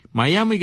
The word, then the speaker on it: Mayaimi: Miami, que se escribe M-A-Y-A-I-M-I, Miami, Miami.